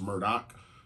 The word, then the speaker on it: Murdaugh: And that last name, Murdoch, it's spelled Murdaugh, but it's pronounced Murdoch.